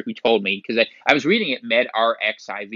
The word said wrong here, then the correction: MedRxiv: because I'm like, oh, that's prescription. But I could actually see it's probably the Greek letter, it would be pronounced Kai, MedRxiv, right?